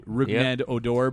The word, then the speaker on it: Rougned Odor: Rough Odor. His name is actually Rougned Odor, but it can be pronounced Rough Odor in some circles of the country.